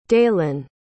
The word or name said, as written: dalan